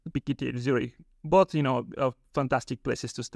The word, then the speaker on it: ETH Zurich: ETH Zurich, yes.